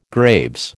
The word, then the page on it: Graves: American English